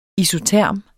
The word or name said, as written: isoterm